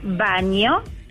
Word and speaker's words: Bagno: ¿Cómo se pronuncia? Baño. – ¿Y cómo se escribe? Bagno. – Belarga A-N-O. – Ah, igual que el baño.